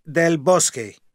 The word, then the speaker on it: del Bosque: It's del Bosque.